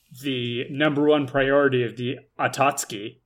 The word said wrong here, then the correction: Akatsuki: their Akatsuki. – Group, Akatsuki? – Yeah, it's not pronounced like it's spelled.